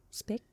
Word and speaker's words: Speich: Ben, je sais pas son nom, comment ça se prononce. Christine Speck. S-P-E-I-C-H. Speck.